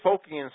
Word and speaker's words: Phocian: The Phocians founded Marseille. – How do you spell that, Phocian? – Well, it would be P-H-O.